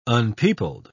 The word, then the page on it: unpeopled: British English